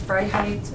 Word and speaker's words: Freiheit: Yes, Freiheit is German for freedom, and that is how you pronounce it.